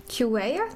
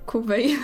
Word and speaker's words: Kuwej: Kuwaya, tak to się czyta? – Kuwaya? Pisze się Kuwej. – Kuwej. Bardzo spolszczona nazwa.